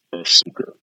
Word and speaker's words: Ahsoka: Ashoka? What is her name? I can't think of her name right now. Ashoka. Ahsoka. Did I say it right? – Oh, Ahsoka.